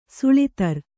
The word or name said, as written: ಸೂಳುತ್ತರ